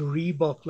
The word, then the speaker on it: Rehbock: Aubrey, it's Rehbock, like the running shoe. – Okay. Because I see yours is spelled R-E-H and the shoes is R-E-E.